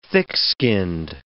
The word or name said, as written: thick-skinned